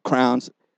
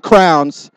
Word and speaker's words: crayons: I know, I'm probably saying it wrong. Hey, crayons. It's my how accent. I said it. Relax. Crayola crayons, okay? Not crowns.